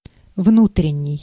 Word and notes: внутренний: /ˈvnutrʲɪnʲːɪj/

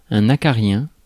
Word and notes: mite: American English

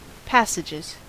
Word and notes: passages: American English

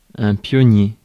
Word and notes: pionnier: France; /pjɔ.nje/

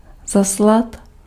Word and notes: envoyer: France; [ɑ̃.vwa.je]

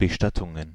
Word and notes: Bestattungen: /bəˈʃtatʊŋən/; German

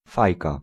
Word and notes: pipe: /paɪp/

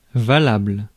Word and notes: acceptable: American English; IPA: /æk.ˈsɛp.tə.bəl/

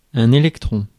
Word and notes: électron: [e.lɛk.tʁɔ̃]

